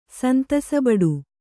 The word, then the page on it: ಸಂತಸಬಡು: kannada